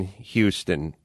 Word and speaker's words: Houston Street: I literally grew up on Houston Street, which is spelled OU, not Houston. It's not, or no, maybe it's spelled phonetically.